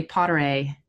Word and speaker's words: Potyraj: It's a hard one. Emily Potteray. It ends in J. It's very Polish.